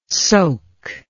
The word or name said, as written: soke